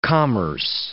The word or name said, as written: commerce